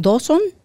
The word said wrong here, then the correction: Dobson: Dobson. Dobson. Dobson.